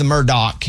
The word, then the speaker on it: Murdaugh: Raymundo, can you give everybody, first of all, it's the Murdoch, even though it's spelled Murdaw.